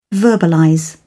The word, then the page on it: verbalize: British English